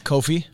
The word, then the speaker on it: Kofi: Was it Kobe? – Kofi. – Kofi.